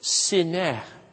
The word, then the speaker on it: sineh: And then there is this hint, because it's a bush that catches Moses' attention, and the Hebrew word for bush is sineh, like Sinai, rhymes with that.